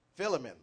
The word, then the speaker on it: Philemon: The prophet was referring to a scripture when he preached this message Philemon, I'm not sure how you pronounce it. Philemon, all right, thank you.